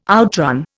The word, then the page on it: outrun: Indian